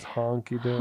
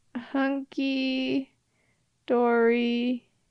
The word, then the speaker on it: hunky-dory: It's honky dory. – Honky dory. Hunky dory. It's hunky.